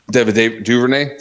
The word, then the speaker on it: Duvernay: Is Duvernay out of Texas. Seemed like Duvernay. Did I not say that right?